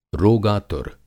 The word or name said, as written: रोगातुर